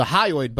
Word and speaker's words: hyoid: And one of the bones that was broken was the hyoid bone. I think I'm pronouncing that correctly. – H-Y-O-I-D.